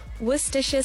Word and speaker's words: Worcestershire: And the correct pronunciation actually is Worcestershire sauce.